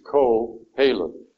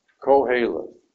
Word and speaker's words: Kohelet: And beginning with Kohelet, again, it is Kohelet. Think of the Q as a K, all right, which will help you to pronounce it correctly. Kohelet, or also in the Greek version, it is Ecclesiastes.